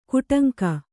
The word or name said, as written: ಕೊಡಂಗೆ